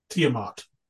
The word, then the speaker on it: Tiamat: Number one was Tiamat. Is that how you say it? – That's how I say it. – Yeah. Tiamat.